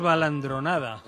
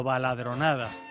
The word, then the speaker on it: baladronada: No diremos balandronada, sino baladronada.